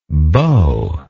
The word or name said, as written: beauish